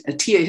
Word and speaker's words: Etteilla: This person named Atiyah, his name is spelled Etteilla.